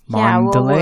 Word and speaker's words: Mandalay: And then we'll fly to um, how do you say it? Man Mandalay? – Mandalay? Yeah, we'll, yeah, we're gonna hit up Myanmar.